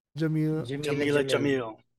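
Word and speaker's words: Jameela Jamil: Jamila Jamil.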